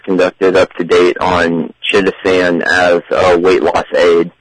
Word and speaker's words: chitosan: I pronounce it chitosan. How do you pronounce it? – Yeah, same way.